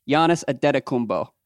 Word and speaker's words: Antetokounmpo: I think you pronounced it Antetokounmpo. I think it's Giannis Antetokounmpo, right? – Sure.